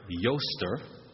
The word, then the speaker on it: Eostre: It comes from a word, Eostre,